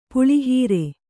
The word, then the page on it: ಪುಳಿಹೀರೆ: puḷi hīre